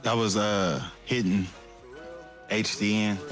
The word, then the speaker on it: HDN: Yeah, it's he spells it HDN, but it's pronounced Hidden.